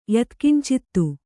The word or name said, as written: ಯತ್ಕಿಂಚಿತ್ತು